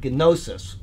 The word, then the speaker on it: gnosis: They were just people that did these things, and the word Gnostics comes from the word gnosis. – Knowledge. – I pronounced the G, but I didn't need to.